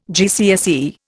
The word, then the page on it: GCSE: Indian